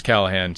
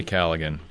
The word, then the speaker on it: Callaghan: It's Callahan. It's G in front of the H, though. – CallaghanVineyards. – Vineyards. – So correctly pronounce it Callaghan. – We say Callaghan, but we're told we're definitely incorrect. Callahan, it is.